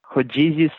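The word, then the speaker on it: rodízio: Here the family is eating a, how do you say this, Rodizio style meal? – Yeah, Rodizio style churrasco, which churrasco in Brazil is just like assorted meats from different parts of like cows, pigs, chickens, anything.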